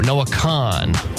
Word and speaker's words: Noah Kahan: Oh, Noah Kahan. – Like con artist. – Yeah, that's it.